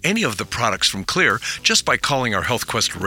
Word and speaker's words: Xlear: Also, you can find all of Clear's xylitol-containing products, that's the Clear nasal and dental products, because they've got your bases covered. Oh, by the way, Clear as in clear is spelled X-L-E-A-R.